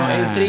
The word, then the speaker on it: El Tri: Well, El Tris, am I pronouncing that correctly? Okay. El Tris. I have no idea who the fuck El Tris is, except for the fact that it's probably a soccer player. – It's El Tri, it's the nickname given to the national team because of the tricolor on the flag.